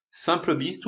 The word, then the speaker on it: simplebiz: Alors eric.solution, solution avec un S à la fin toujours, slash simplebiz, tout attaché, euh, S-I-M-P-L-E-B-I-Z. Eric.solution slash simplebiz ou simplebiz, tu prononces comme tu veux.